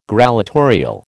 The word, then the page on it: grallatorial: American English